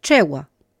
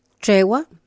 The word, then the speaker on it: trewa: Es tregua, pero se escribe X-E-W-A. Con el grafemario unificado, tregua, se pronuncia de la misma manera, pero se escribe T-R-E-W-A.